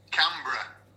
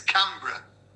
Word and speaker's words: Canberra: It's pronounced Canberra. So, like the br, but not like br. Or brahoo. Or brahoo. Oh, so it's Canberra. As if you were saying Edinburgh, but it's with a can in the front.